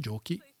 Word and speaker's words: giochi: Et pour prononcer giochi, il faut mettre un H. – Oui. – C'est H-I.